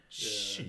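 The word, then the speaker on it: Xu: Can you say your last name again, please? Chew? Yeah, nobody can say it.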